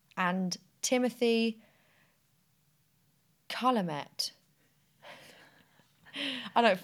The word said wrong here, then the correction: Chalamet: Chalamet. – Okay. Fucking hell. Take two. Timothy Chalamet.